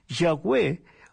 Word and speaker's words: Yahweh: Cuando se agregan las vocales se pronuncia Yahweh, que en español sería Jehová.